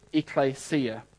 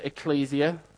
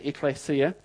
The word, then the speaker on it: ekklesia: The word in the Greek, as many of you hopefully maybe probably aware of, is the word ekklesia. Others pronounce it ecclesia. The pronunciation doesn't matter too much. We don't know exactly how the Greeks spoke, but I pronounce it ekklesia.